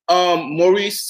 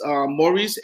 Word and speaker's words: Maurice: Maurice. So it's Maurice, M-A-U-R-I-C-E. Smith, S-M-I-T-H, music with a Q. M-U-S-C-I-Q.